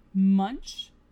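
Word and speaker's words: Munsch: And it is a story by Robert Munch, and that's spelled M-U-N-S-C-H. I believe it's pronounced Munch.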